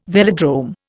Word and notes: velodrome: Indian

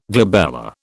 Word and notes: glabella: British English

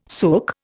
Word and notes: sook: Indian

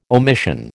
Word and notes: omission: American English

